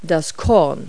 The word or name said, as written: kernel